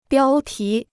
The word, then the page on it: 标题: biāo tí